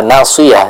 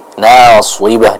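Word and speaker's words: nasibah (ناصبة): Tadi juga Ibu di ayat yang ketiga ini kita perhatikan bukan nasiyah tapi nasibah dengan huruf ba.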